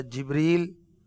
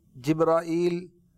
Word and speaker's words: جبریل: اچھا جبریل، جبرائیل، جبرائیل، یہ ان تمام انداز سے آپ کا نام مبارک لیا جا سکتا ہے، تینوں تلفظ درست ہیں